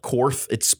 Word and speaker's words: Korth: It's spelled H-O-R-S-T. There's a German high-end firearm manufacturer called Korth. It's actually called Kort, but it's spelled K-O-R-T-H.